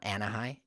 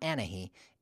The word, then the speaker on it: Anahi: So my daughter, for example, her name is Anahi, and it's spelled A-N-A-H-I. It's not Anahi. It's not Anahi. It's Anahi, and the H is silent.